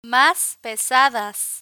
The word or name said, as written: mas pesadas